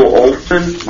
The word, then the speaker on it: Olesen: Yes, my name is Neil Olson. My last name is spelled O-L-E-S-E-N.